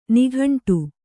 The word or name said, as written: ನಿಗಂಠ